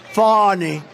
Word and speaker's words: Fani: It's spelled Fanny like your ass, right, Fanny? But when she became DA, she decided to add a little French, a little fancy, Fawny.